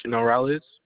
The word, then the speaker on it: Norales: Tyreek Norales.